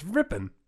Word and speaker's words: Rypien: Is it not Rypien? – It's Rypien. – It's R-Y-P-I-E-N. That's Rypien? – That's Rypien. – It's pronounced Rypien.